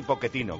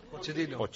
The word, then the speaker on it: Pochettino: pero que no sabes cómo se pronuncian Mascherano y Pochettino, que se dicen los dos con sonido Q, porque para eso son apellidos de origen italiano.